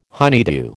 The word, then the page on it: honeydew: American English